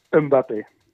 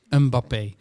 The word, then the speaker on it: Mbappé: hoe zeg je dat? Mbappé? Of mag je die M niet uitspreken? – Mbappé. – Mbappé.